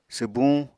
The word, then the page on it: swbuñ: apatani